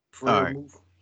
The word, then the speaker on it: Freiermuth: Freiermuth. – Freiermuth, 4 receptions, 57 yards.